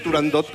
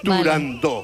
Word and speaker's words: Turandot: pues yo también lo digo mal y digo turandot como todo el mundo. Turandó. Pero para que quede claro... – Entonces, todos tontos ya. – Para que quede claro, es turandó.